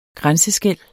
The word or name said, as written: grænseskel